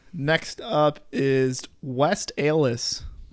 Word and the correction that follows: West Allis: It's a Wisconsin city, so of course it doesn't make sense, but West Allis.